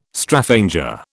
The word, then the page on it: Straphanger: British English